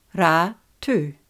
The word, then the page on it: Ratu: Ra–tu